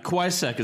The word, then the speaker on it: Cusack: Whatever it is, Quaysack, however you want to pronounce That's fine. Quaysack is good.